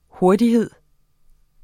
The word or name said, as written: hurtighed